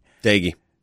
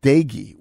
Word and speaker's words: Doege: that's just Seth and the way his name is spelled is D-O-E-G-E. – Daigie. – Is it Daigie? – Yeah. – Seth Daigie won that title.